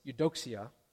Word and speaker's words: Eudoxia: So, because of his supreme devotion to Jesus, the empress at the time, whose name was Eudoxia, I'm not mispronouncing that.